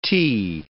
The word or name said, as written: ti